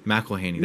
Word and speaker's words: McElhaney: McElhaney. That's how you pronounce it? – Yes. – I was saying McElhaney.